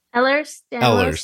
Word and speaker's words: Ehlers-Danlos: Ehlers-Danlos syndrome.